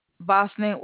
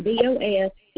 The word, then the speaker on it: VOSNET: VOSNET, which is spelled V like in Valerie. OSNET/. – I got BOSNET what slash? – /.